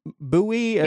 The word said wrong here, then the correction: Mbewe: I've tried it several thousand times. Yeah. – Mbewe. – Mbewe. Sorry. You know, I've been talking to friends probably about you for years, and I think I've pronounced it wrong every single time.